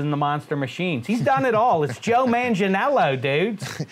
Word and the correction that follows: Manganiello: Well, it's Manganiello. Manga. Yeah, I mean, Don Air and McKees Rocks, they do the soft G, but up in Mount Lebanon. – Not me, dude. – It's a hard G.